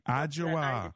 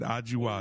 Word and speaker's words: Adjoa: Adejo on the line. Hi, Adejo. Did I say your name right? – It's Adjoa. – Adjoa. I like it. Adjoa. Yes. It's much prettier the way you say it, too.